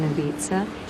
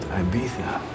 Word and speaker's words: Ibiza: His wife's in Ibiza. She left her diamonds behind, though. Worried they might get stolen. – It's pronounced Ibiza.